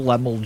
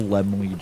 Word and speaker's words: Laemmle: for all this time that I've called him Carl Lemmel Jr., and it's Carl Laemmle Jr. So,